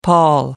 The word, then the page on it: pall: American English